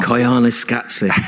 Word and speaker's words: Koyaanisqatsi: Koyaanisqatsi.